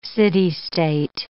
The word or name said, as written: city-state